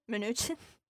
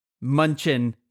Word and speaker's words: Mnuchin: Mnuchin. – Yeah. So I, in my whole life, Munchin.